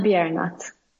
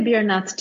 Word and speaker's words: Biernat: Is it Biernat? How do you say it, Swedish? – Gordana Biernat. – Biernat. – But you can say Biernat too. It's okay. It's okay.